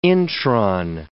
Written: intron

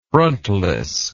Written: frontlessness

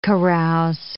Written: carouse

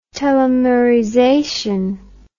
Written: telomerization

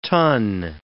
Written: tun

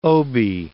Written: obi

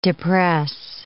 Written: depress

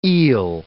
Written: eel

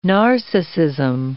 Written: narcissism

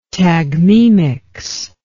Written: tagmemics

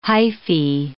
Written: hyphae

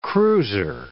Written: cruiser

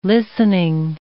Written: listening

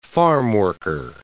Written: farmworker

